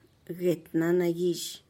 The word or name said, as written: get na nagizh